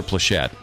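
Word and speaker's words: Pleshette: Her friends called her Pleshett. – Pleshett. – Suzanne Pleshett. – Pleshett. – Stress okay. – on the last syllable there.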